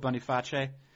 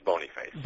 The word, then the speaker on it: Boniface: Well, there was a pope, let's see, there are 9 Pope Bony Faces, might be known to the people as Pope Boniface. – Right, but it's Bony Face. – But it's Bony Face. He pronounced it Bony Face, that's how I'm going to...